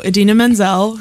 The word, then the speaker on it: Idina Menzel: But so Idina Menzel, who, as I was so glad they noted last night, is pronounced exactly as it's spelled.